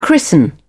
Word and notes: christen: British English

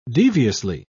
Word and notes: deviously: British English

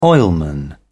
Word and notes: oilman: British English

